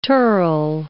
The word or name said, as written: tirl